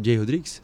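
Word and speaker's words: Jay Rodriguez: O Jay Rodrigues, pode falar Jay Rodrigues? Sim. Staff confirmou aqui a pronúncia.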